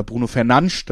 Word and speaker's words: Bruno Fernandes: Aber wichtigste Spieler natürlich Bruno Fernandes oder Bruno Fernandes, das kann sich jeder selber aussuchen. Das wäre die korrekte Aussprache, habe ich gelernt.